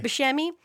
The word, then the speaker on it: Buscemi: Steve Buscemi, am I saying his name right? – Buscemi.